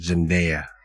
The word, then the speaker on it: Zendaya: Zendaya. Is it Zendaya? Zendaya. – The Americans pronounce it Zendaya.